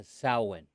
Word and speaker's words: Samhain: It's also, tell me how to say that word. Sam. – It's a Samhain. – Samhain.